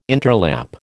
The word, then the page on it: interlap: American English